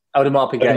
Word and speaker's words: Audemars Piguet: Am I saying that correctly? – Audemars Piguet, yeah.